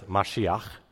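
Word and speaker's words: Mashiach (מָשִׁיחַ): So on the left, this is a Hebrew word. You pronounce Mashiach.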